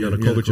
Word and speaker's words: Yanukovych: So in that situation, say it's 2013, and Yanukovych is sitting there, and he's kind of like, I'm not saying his name correctly. – Yanukovych